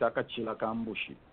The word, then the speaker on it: Akachi Lakambushi: Kachila Kambushi, or is it Akachila Kambushi, which is the right way to say it? – I think you would say Akachi Lakambushi.